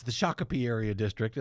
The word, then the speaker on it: Shakopee: Brad Tabke was declared the winner last week of the swing suburban how do you say it? Shakopee. I think it's Shakopee. – Okay. – Shakopee. That's it.